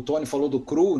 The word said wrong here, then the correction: Crow: né? Crow. – Ah, é verdade. – Crow, é. Dessa época também.